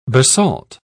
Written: basalt

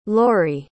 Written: loree

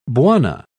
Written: bwana

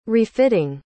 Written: refitting

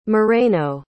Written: moreno

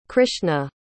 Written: krishna

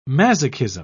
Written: masochism

